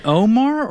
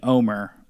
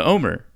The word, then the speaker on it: Omer: Is it Omar or is it Omer? I think everyone's saying Omer on the show. – Oh, I've been pronouncing his name wrong. – Was too until tonight when a bunch of people said Omer. – Oh, okay. So Omer, and he comes out and he says, guys, like, I'm not looking for idols.